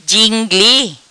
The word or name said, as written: Gingli